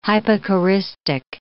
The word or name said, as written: hypocoristic